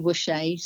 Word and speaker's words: Wusche: So if you look up Vicki Wusche, so Vicki is spelled V-I-C-K-I, and then the Wusche is usually the easy bit, W-U-S-C-H-E.